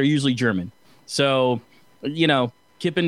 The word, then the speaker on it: Kipon: how do you think you pronounce this? K-I-P-O-N. – Yeah, I'd say Kippen. – Kippen, okay.